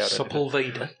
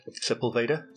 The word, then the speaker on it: Sepulveda: So, a Texaco garage in Sepulveda, California. Mm-hmm. I don't know if I'm saying that right. – Yeah, Sepulveda. – Sepulveda.